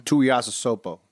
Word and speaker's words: Tuiasosopo: The story of Te'o and his fake girlfriend is well known, but the story of Ronai, how do you say the last name? – Tuiasosopo.